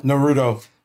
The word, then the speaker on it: Naruto: How do you pronounce it? Naruto. – Naruto.